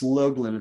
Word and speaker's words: Logelin: Close. It's Logelin. It's a long O.